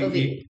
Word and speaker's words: Ove: I've heard it pronounced multiple ways. – Ove. Ove. Yes.